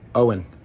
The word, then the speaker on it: Owen: Ewan? – Owen. – Ewan. – No, just Owen. Yeah, it's just plain old Owen. It's so easy.